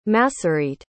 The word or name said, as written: Masorete